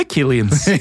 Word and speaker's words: Killeen: For Donald Killeen, han var nemlig en irsk-amerikansk mafiaboss, som vi begge uttalte på veldig skotsk. – Ja, ja. Ja eller egentlig Killeen. Ja. – Killeen.